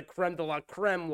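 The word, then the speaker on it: crème de la crème: But the funniest scene of them all, this one is the creme de creme. I just said that wrong, but it's the creme de la creme la, whatever, of spider sequences, is when Jake Sully and Neytiri's kids get captured onto the boat with the colonel.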